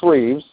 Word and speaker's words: Reeves: It's Chris Reeves, just the way you say my name.